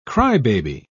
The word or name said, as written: crybaby